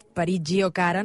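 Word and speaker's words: Parigi, o cara: Déu-n'hi-do, està sonant ara aquest Parigi Giocara, no sé si ho he pronunciat bé, Xavi, aquesta...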